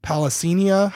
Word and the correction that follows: Plasencia: how do you say his last name? – Plasencia.